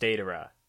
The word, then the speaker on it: Deidara: But yeah, they're mentioning Akatsuki, and we see Dedaria and Sasori come in. They're on their way to the sand. – It's actually pronounced Deidara, I think.